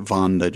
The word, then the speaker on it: Wanda: And she, her name is Wanda, but she pronounces it Vonda, just like the character in the book.